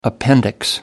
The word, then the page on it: appendix: American English